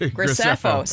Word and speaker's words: Graceffo: I think that McGreevy and Gordon Grisefko, Grisefko I can't even say that right. Graceffo.